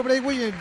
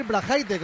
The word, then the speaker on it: Braithwaite: Y bueno, dicho Braithwaite, debería decir Brahaite, como dice José María Minguella.